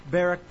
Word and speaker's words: Barak: Barak, I suppose, if you'd like to pronounce it that way. But Barak, probably the emphasis is on the first syllable.